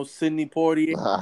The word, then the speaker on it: Sidney Poitier: You know, Sidney Poitier.